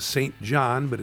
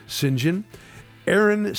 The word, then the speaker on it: St. John: Now, Aaron St. John Kelly, if you know what St. John is, it's St. John, but it's pronounced St. John. Aaron St. John Kelly is the Director of External Relations.